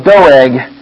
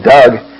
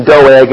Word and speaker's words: Doeg: Now, one of the servants of Saul was there that day, detained before the Lord, and his name was Doeg the Edomite. I'm very thankful for Stan Newton being here last week to help me out here on the pronunciation of Doeg. I always called him Doug.